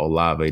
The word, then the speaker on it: Olave: And I thought Olave definitely looked the part. – Yeah, I think you said it right.